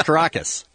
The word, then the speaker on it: Caracas: I'm busy working at a hotel in Carcass. – I think it's Caracas. – Oh. I've been saying it wrong all this time.